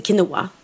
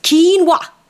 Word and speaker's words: quinoa: Ja, men nu är det så att det uttalas inte quinoa. Det uttalas quinoa.